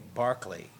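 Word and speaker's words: Berkeley: The jazz band's next number is a nightingale sang in Barclay, pronounced the British way, Barclay Square, by Manning Sherwin and Eric Moswich, arranged by Roger Holmes.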